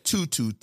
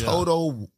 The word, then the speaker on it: Toto: I said tutu, T-O-T-O, Washlet. But, you know, I'm a Geechee from South Carolina. – I call it Toto. – Toto. – Like Toto. – Like the dog.